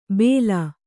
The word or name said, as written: ಭೇಲ್